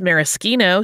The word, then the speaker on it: maraschino: The Italian pronunciation, since it's Ezra, is maraschino, so we'll go with that.